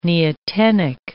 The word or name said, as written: neotenic